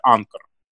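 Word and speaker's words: Anchor: Пан Кушнір і пан Ідолов кажуть, що Анкор не так уж взагалі погано, а скоріше Анкор, правильно читається.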